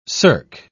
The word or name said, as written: cirque